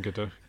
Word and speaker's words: Gadot: You, I think you pronounced the T in Gadot.